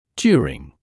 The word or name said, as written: during